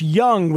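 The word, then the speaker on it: Jung: That is Josh Young with a J. I think it's a soft J, like yogging.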